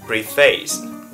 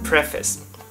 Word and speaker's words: preface: Ví dụ như chữ này, rất nhiều bạn đọc là chữ preface, bởi vì phần này đọc là pre, phần này đọc là face, thế thì ghép lại là preface, nhưng thực sự nó đọc là preface, preface, preface.